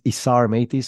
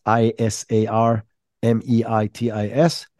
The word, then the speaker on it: Isar Meitis: So my name is Isar Meitis. So it's Isar Meitis.